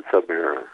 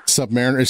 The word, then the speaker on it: Submariner: Stan said Submariner. – Submariner. If Stan says Submariner, then I'm saying Submariner.